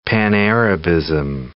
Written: Pan-Arabism

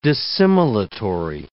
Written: dissimilatory

